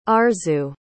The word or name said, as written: Arzu